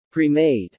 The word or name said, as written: premade